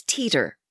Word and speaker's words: Teeter: My last name at the time is Teeter, rhymes with Peter.